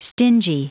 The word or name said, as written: stingy